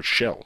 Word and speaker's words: Schell: So rest in peace, Maximilian Schell, or Schell, is how you pronounce his name.